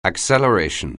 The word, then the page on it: acceleration: anglais